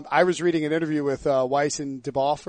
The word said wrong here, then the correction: Benioff: Benioff? – Yeah, Benioff, whatever.